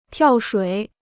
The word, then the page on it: 跳水: tiào shuǐ